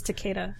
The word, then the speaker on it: Takeda: And, Monstrous, the art by, Santa Takati? I don't know how to say her last name. – I think it's Takeda. – Takeda? Yeah. Santa Takeda, yeah.